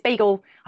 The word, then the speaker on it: Beigel: So, but it's bagel. I can never say it properly. It's like bagel. B-E-I-G-E-L.